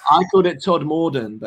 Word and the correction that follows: Todmorden: but it's Todmorden, I've been told by a mildly aggressive local via Instagram.